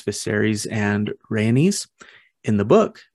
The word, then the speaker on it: Rhaenys: So Rhaenys is how I say that name.